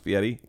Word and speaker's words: Fieri: And by the way, it's pronounced Fieri.